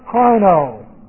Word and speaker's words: krino: This is an aorist participle of the word krino. Here's our word. K-R-I-N long O.